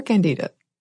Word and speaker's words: Candida: And it's usually of the genus Saccharomyces or Candida. – Yes. – I hope I got close on that pronunciation. – No, I think that was spot on.